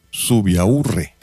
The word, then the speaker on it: Subiaurre: Y para que vean que no solamente en México se dicen tonterías, ahorita les voy a poner a una comunicadora española, Isabel Subiaur. ¿Cómo se, cómo se pronuncia, güey? Isabel Subiaurre. Subiaurre, bueno. Isabel Sub Subiaurre es una presentadora en España y es que en Canarias pues hizo erupción un volcán.